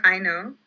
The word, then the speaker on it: Aino: So Ikata is kind of a child, and her motherly figure, Haino, am I pronouncing that right? – I call her Aino. – Aino, okay, mm-hmm.